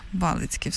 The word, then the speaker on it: Балицький: Так, і скажіть щодо вашого прізвища, де правильно його наголошувати. – Балицький. – Балицький, все, супер.